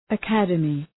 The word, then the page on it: academy: ə’kædəmı